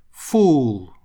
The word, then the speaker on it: fool: Fool, дурак. Обратите внимание на произношение. В предыдущем мы говорили у кратко full, а здесь fool. У обязательно тянем.